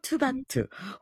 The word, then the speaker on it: TXT: TXT라고 읽지 않고 투바투 혹은 투모로우 바이 투게더라고 읽는 그룹입니다.